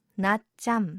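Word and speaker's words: な っ ち ゃ ん (낮잠): ていうのが屋上の奥さんとはね屋上の上ということですねでなっちゃんと聞こえたのは、まあ、韓国語でなっちゃんなっちゃんって言うんですねでこれお昼寝のことなんですよねで発音のポイントは日本語のねなっちゃんっていう名前になっちゃんではなくて、なっちゃんって言ってね、無を言う感じ、口を最後にね、閉じるんですよ。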